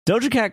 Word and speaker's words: Doja Cat: Doja Clat. Doja Clat? Clat? – Doja Cat claims. Did I say that right? – Yeah, you did.